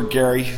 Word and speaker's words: Gerry: Elbridge Gerry of Massachusetts, right. – or Gary, some people tell me. – Nobody knows. – It's pronounced differently.